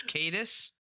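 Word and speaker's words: Caetus: And when it flips, it's now Cadius, Sea Tyrant of Segovia. – Cadius. – I was so proud. I was like, I haven't mispronounced a word yet, but it's Cadus.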